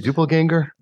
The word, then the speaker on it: doppelganger: Well, you know, your doppelganger again. How do you say it? Doppelganger? – Doppelganger.